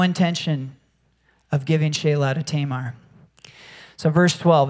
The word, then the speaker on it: Shelah: By the way, the pronunciation is Shelah. And no man wants to be called Sheila, even though it looks like Sheila. It's Shelah.